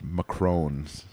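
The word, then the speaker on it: macarons: There were two macaroons, which I have come around on. – I've been told they're pronounced macrones. – Macrones.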